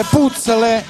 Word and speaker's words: puzzle: Si scrive puzzle e si pronuncia puzzle.